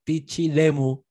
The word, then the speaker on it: Pichilemu: Mira, tú, y este tema es dedicado a Juan Soto, nuestro primer Patreon aguante, a quien ya Pichilemu de ahí esta vez lo dije bien, ya le dije que era de Pichilemu una vez.